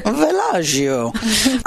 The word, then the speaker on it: Villaggio: Villaggio is spelled VillaGio. – Beautiful. – And that is village in Italian. – Oh, thank you. Villaggio.